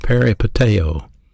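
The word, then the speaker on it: peripateo (περιπατέω): That's the Greek word for walk is pronounced peripateo. Peripateo.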